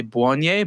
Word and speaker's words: Blonie: The websites that you want to look at are going to be, it's spelled B-L-O-N-I-E. We've been saying it the proper way, Bonnie, but for people that are spelling it online, it's B-L-O-N-I-E.